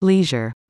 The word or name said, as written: leisure